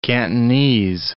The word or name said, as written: Cantonese